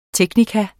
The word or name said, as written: teknikum